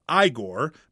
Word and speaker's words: Igor: How long before Frederick and Igor, pronounced Igor, played by Bill Garcia, get back in the family business?